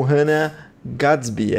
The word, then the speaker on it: Hannah Gadsby: Tá lá, Nanette, com Hannah Gadsby.